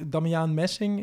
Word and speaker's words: Damiaan Messing: Damiaan Messing, is dat? – Spreek je zijn naam goed uit? Ja, helemaal goed.